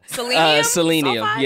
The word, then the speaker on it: selenium sulfide: The active ingredient in a shampoo, selenium sulfide. – I don't believe that's how it's said. – It's probably not. – Selenium. Sulfide? – Yeah, selenium sulfide.